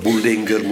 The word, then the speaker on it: Bullinger: Zodat Bullinger, ik heb u dat verteld, Bullinger moet ik zeggen, de Duitse uitspraak.